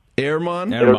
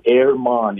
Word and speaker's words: Germán: What is the official pronunciation of Germán? – It is Ederman. – Ermon? – Like Ermon. Yeah, like air, like A-I-R, Ermon.